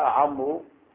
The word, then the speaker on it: عمرو: نعم نعم، لكن لما يجي حتى ما طيب جاء عمرو او جاء عمرو فنعمل واو، جاء عمر بدون واو، معنى النطق واحد، هو جاء عمر جاء عمر، النطق واحد لكن الكتابة اختلفت بالواو.